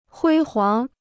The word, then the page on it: 辉煌: huī huáng